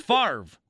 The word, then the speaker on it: Favre: Jay Lilge wrote, "It's pronounced Favre, which comes all too close to farce. Or barf," like he just invented comedy, rhyming comedy.